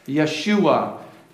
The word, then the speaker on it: Yeshua: Yeshua. In the Hebrew, Joshua is how we would pronounce it.